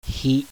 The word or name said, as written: HI